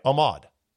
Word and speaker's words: Ahmaud: Ahmaud Arbery? Arnaud Arbery. I should get his name right, lest I get criticized. Type this in. A-R-B-E-R-Y. Oop, botched that one. No, hold on a second. If I only had hold music. Arbery. Yeah, Ahmaud.